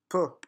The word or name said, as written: peu